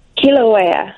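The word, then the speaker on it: Kīlauea: Kilauea.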